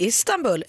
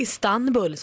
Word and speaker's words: Istanbul: Ja, visst är det Istanbul, eller Istanbul som vissa vill uttala det.